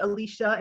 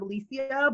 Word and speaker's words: Alicia: Like, I always struggle with people calling me Alicia instead of Alicia, but when they heard the Fajardo, they was like, okay, we know some Spanish is in there, so maybe it is Alicia.